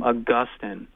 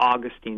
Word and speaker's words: Augustine: We pick that up in this guy named Augustine, or Augustine. You can pronounce it either way that you would like.